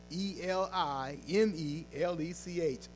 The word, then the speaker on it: Elimelech: And so the story of Ruth starts with a man named Elimelech. Elimelech, if I can say that right. I know I'm going to mess that up a couple times. E L I M E L E C H, Elimelech. He was a descendant of Judah.